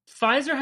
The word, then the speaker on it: Pfizer: Pfizer has a silent P.